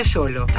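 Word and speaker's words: Xolo: Con X se escribe solo, pero se pronuncia yolo.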